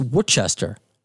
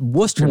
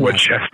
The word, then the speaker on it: Worcester: And we were reminiscing about the time where we saw you, and I mispronounced it as a New Yorker first. I was like, what's Worcester? And Worcester. – Worcester. – Worcester, Massachusetts. We saw Against Me, Serge Tankian, and the Foo Fighters, and that's still one of my top concerts ever.